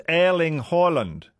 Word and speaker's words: Erling Haaland: Erling Haaland is pronounced in Norwegian like, it's Erling Haaland.